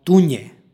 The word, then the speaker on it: tunje: stihu pročitali smo tunje. Predlog tunje izgovara se sa nj u 2. slogu.